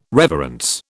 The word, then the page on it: reverence: British English